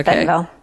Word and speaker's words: Bentonville: because I know you've piqued my interest in Bentonville. I got the name right. Is that correct? – Yep. – Okay. – Bentonville.